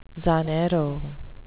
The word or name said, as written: zanjero